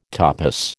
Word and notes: topos: American English

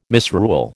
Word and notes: misrule: American English

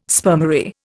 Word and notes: spermary: Indian

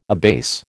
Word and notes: abase: American English

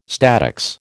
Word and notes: statics: American English

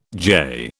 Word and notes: jay: British English

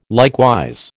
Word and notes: likewise: American English

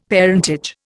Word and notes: parentage: Indian

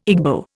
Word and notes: Igbo: Indian

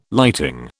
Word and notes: lighting: British English

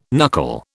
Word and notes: knuckle: British English

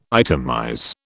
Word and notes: itemize: British English

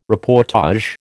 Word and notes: reportage: American English

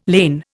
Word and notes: lain: Indian

